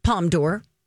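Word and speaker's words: Palme d'Or: She said pronunciation for palm d'or is palm d'or, meaning the golden palm.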